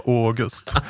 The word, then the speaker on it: August: Hon menar också att det framgår i brev och berättelser från författarens omgivning att han vill uttala som Å. Och de bevisen skulle jag vilja se. För hon är den enda som har läst de breven där han är så tydlig med att det ska vara August.